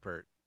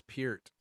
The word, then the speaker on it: Peart: It looks like Pert. – I just learned this, like just now. Okay. – I'm just learning this guy exists. So, so you're fine with the pronunciation of his name. – You'll be in a conversation 10 years from now and be like, oh, wait, it's Peart.